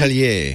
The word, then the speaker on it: Atelier: These are from Black Forest Atelier. Is that how you pronounce Atelier.